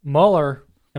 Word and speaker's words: Mueller: Mueller... Am I saying that right? – Yes.